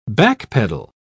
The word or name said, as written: backpedal